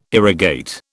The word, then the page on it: irrigate: British English